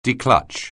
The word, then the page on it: declutch: anglais